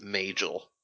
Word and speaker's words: Majel: And this guy says it's Majel.